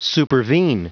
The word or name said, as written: supervene